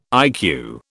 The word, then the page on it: IQ: British English